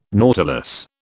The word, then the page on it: nautilus: British English